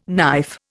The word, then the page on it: knife: Indian